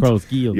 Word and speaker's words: Carlos Gil: Carlos Gil.